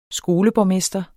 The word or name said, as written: skoleborgmester